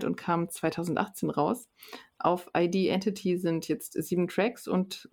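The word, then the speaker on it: ID.Entity: Auf der 4 haben wir Riverside mit dem Album ID Entity, spricht man es, glaube ich, aus. Das ist so geschrieben, dass man es auch Identity aussprechen könnte.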